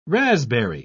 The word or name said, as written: raspberry